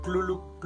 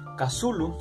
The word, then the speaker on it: Cthulhu: Lovecraft transcribe la pronunciación de Cthulhu como Clulu que esto en castellano sonaría más que Clulu, Clulu.